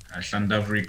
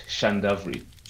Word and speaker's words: Llandovery: I left Gateway and went to Llandovery College, which is in Wales. – So is that how you say it? When you say L is in LL Welsh, it's Llandovery. – Llandovery.